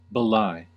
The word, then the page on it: belie: American English